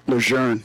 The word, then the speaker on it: Lejeune: Lejeune. Yeah. It's spelled, but it's said different. – Yeah, even with no R in it. I'll take your word for it. It's Lejeune, yeah.